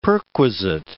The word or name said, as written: perquisite